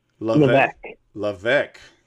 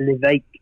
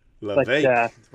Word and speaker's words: Levesque: The S is silent. – Okay, so say it for me. – Levesque. Maybe. Or if you're a good Frenchman here in Canada, it's Levesque. Levesque is just fine.